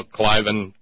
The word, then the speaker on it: Cliven: But I was glad to have it back because today one of the AATTP articles posted an article that said, what if Cleveland... How do you say his name? Cleveland Bundy? – Yeah. Well, Cliven.